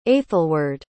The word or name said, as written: aethelweard